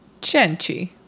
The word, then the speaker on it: Cenci: Beatrice Cenci. It's Italian. C-E-N-C-I. I looked up how to say it, and that was it.